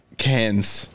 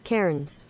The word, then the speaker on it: Cairns: Cans. – What the hell is that? – Okay, we got the pronunciation. Cans? Sounds like K-E-H-R-N-Z. Go for it. – Play it. – Karens.